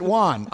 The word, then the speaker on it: Wan: They pronounce it Wan. I'm tight with the Kenobis. They are of, because Mike is a letter carrier in Long Island, they are of the Syosset Kenobis, and they have told me that the name is pronounced Wan.